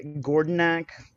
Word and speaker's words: Gordinaak: And then we've got our illustrious guest and creator of the Mothership product, Gordonok. Is that how you pronounce it? – Oh, wrong way. This way. – Yeah, Gordonok.